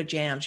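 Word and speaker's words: jamb: Then, now that's all spelled J-A-M, which mm-hmm. is also the abbreviation for Jamaica, believe it or not. There's another jam with a B on the end that is silent. That is the side post or lining of a doorway or other type aperture. You have window jams, you have door jams.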